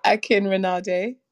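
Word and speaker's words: Akinrinade: Akin Rinade. – Akin Rinade. Okay. So it's A-K-I-N-R-I-N-A-D-E. N-A-D-E, acronym.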